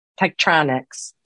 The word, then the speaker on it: Tektronix: we need more definition behind tech te- i can't say it Tektronix. – Did I uh-huh. say it right? it. nailed it.